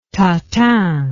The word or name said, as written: tatin